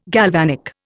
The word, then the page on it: galvanic: Indian